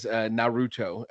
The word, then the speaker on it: Naruto: Naruto, which I don't know if I pronounced that right.